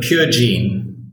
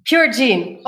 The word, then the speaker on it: Purgene: So before we start into what you're doing and what is your company doing, so it's spelled Purgene or Purgene? How do you pronounce your company correctly? – We call it Purgene. – Purgene, okay. – Purgene, that's right, yeah.